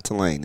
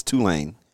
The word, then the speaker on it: Tulane: And you have to pronounce it you have to pronounce it correctly. It's not Tulane. It's Tulane.